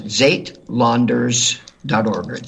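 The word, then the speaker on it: Suidlanders: Folks, it's S-U-I-D, Landers, L-A-N-D-E-R-S, or Landers, dot org. And they pronounce it, in a Germanic way, Zaitlanders, dot org.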